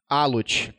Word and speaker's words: Alot: Diretor técnico da Lote Latinoamérica. Ah, exato. – Alot. – É. – Não, Alot, não tem problema, a é gente pronuncia errado às vezes aqui, é, é a vida.